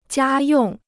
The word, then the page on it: 家用: jiā yòng